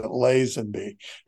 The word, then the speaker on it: Lazenby: And I will tell you that Jack Kent Cook told me once that I didn't know how to pronounce my own last name. – Most importantly, what was your response? And can you say that on the air? – I said, well, my father pronounced it Lazenby.